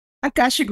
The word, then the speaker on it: Akashic: She also teaches, I think it's, how do you say, Akashic? – Akashic Records, yeah. – Oh, I got it right.